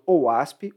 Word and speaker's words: OWASP: Como ela é uma aplicação web, é, vale lembrar que existe uma comunidade aberta chamada OWASP. OWASP se escreve O-W-A-S-P e essa OWASP tem uma série de recomendações de segurança para aplicações web.